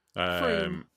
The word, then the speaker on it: Frome: Frome.